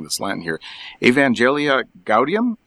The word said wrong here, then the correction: Evangelii Gaudium: and Laud- Evangelii Gaudium, which is an apostolic exhortation.